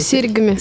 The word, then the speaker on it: серьгами: Как, кстати, правильно, серьгами или серьгами? Серьгами.